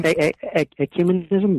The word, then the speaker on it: ecumenism: I'm probably going to butcher this word, right? Ecumenism. Is that right? – Ecumenism, but yeah. – Ecumenism? – You were close.